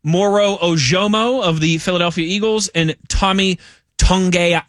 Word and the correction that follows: Togiai: Is it Tongai? Is that how you pronounce his last name from the Texans? – Togiai.